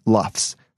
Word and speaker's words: LUFS: Perceived loudness is now commonly indicated by loudness units relative to full scale, or LUFS, which is pronounced LUFS for short. In the past, it was also called loudness K-weighted relative to full scale, or LKFS, and there used to be some technical differences between LKFS and LUFS.